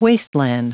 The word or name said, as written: wasteland